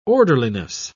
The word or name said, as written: orderliness